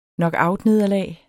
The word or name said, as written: knockoutnederlag